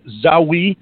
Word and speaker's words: Zaoui: It's David Zawi. It's spelled Z-A, and then if you're French, you know the word for yes is oui, O-U-I. That's how you spell it, Z-A-O-U-I. David Zawi.